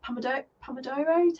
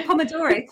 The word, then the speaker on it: Pomodoro: Have you ever tried the Pomodoro technique? I don't know if I'm pronouncing that correctly. – No, you have. I think you've pronounced it perfectly. A Pomodoro technique.